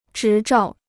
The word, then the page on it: 执照: zhí zhào